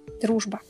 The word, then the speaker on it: Дружба: Дружба. – Wait, what? – Дружба. – Дружба? – Yes. – Wait, am I saying it correctly? – Oh, it's so cute. It's friendship. You said it correctly.